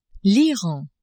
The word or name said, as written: Iran